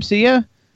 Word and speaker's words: Sia: Is that how you pronounce her name, Sia? – Yeah, yeah.